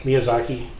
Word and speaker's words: Miyazaki: Miyazaki. I can never pronounce Japanese. – I've heard of Miyazaki. – Miyazaki, yeah. So, real traditional Japanese animated style.